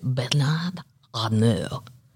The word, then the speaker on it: Bernard Arnault: Bernard, I'll say it like the French way, Bernard Arnault, A-R-N-A-U-L-T.